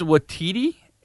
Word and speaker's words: Waititi: Is his last name easier? Is it Watiti? – It's Watiti. It's Takai Waititi.